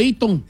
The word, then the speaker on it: Eaton: ¿O Eaton? – Eaton. – ¿Qué le dice Eaton? – Well, that's how you say it, Eaton.